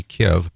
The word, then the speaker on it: Kyiv: Yeah, we have a friend in Kyiv, actually, which used to be Kiev, but for some reason they changed the pronunciation.